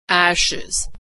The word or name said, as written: ashes